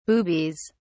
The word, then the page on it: boobies: British English